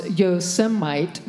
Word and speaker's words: Yosemite: It's spelled Y-O-S. E-M-I-T-E. So we would naturally assume that it is pronounced Yosemite, but it is somehow pronounced Yosemite. How? Just how?